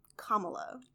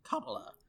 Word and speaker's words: Kamala: So, Kamala. – Kamala.